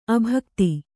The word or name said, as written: ಅಭಿಗೀತ